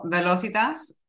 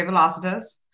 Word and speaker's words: Velocitas: First of all, how do you correctly pronounce your firm? – Well, Velocitas is, you know, I guess, and that, or you could say Velocitas, however you prefer. It's essentially speed in Latin.